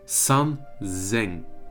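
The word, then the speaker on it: San Zeng (三千): Beim 3000 hast du ein Z anstatt das S und das wird ja Z ausgesprochen. Also San Zeng.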